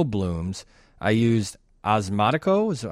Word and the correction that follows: Osmocote: How do you say that? – Osmocote. – Osmocote 2 months ago.